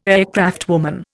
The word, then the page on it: aircraftwoman: Indian